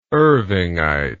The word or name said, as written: irvingite